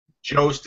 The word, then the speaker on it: Jost: Yeah, I like Jost is the way I've always heard it pronounced.